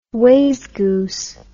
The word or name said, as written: wayzgoose